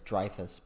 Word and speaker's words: Dreyfuss: Usually over here it's Dreyfuss, but correct.